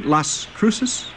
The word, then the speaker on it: Las Cruces: And Las Cruces, do we say that right? Las Cruces? – Very good. That's exactly right.